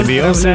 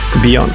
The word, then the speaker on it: Бейонсе: Бейонсе, Жизель Ноулс Картер, наша любимая Бейонсе. – Ага. – У меня есть приятель один, который не знает вообще, как произносятся английские слова. Он говорит Бейонг.